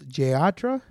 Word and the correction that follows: Yantra: Yantra. – Yeah. Oh, what is it, Spanish? – No, it's Indian. It's an Italian wine, but it's an Indian name.